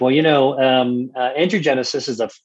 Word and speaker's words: angiogenesis: I totally agree okay angiogenesis i'm saying that right Angiogenesis. – Angiogenesis.